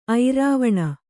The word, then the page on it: ಐರಾವಣ: airāvaṇa